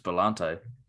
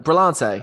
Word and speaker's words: Brillante: Brilliante. Brimmer. – Brilliante. – Daggers. How do you pronounce it? – Well, it's just Brillante. – It is, isn't it? Brillante.